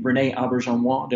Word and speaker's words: Auberjonois: I read that and you're going to have to help me with his name, René Auberjonois.